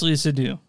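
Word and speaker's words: Léa Seydoux: Leah Sadoux, is that how you say it? – It's Crimes of the Future and it's Leah Sadoux.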